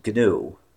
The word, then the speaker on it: GNU: Just that GNU is pronounced GNU. – GNU. Okay, my apologies. – Yes. We don't read it as an initialism, because it's more fun to call it GNU.